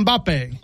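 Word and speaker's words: Mbappé: Mbappe. – Mbappe.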